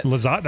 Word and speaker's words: Lizotte: I'm going to pronounce this name wrong. Simon Lizotte. – That's it. – Lizotte, that's right.